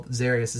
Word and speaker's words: Zarius: Zarius. Z-A-R-I-U-S. But it's my middle name's Reeves, and I spell it R-I-V-E-S.